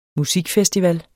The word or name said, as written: Musikfestival